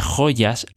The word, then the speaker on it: joya: But then she continues saying, just jumping a bit, tú naciste en este mundo de joyas relucientes. In this case, joya is with Y. And she pronounces it as a Y. She doesn't say joya, she says joya.